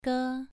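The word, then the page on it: ge: tone 2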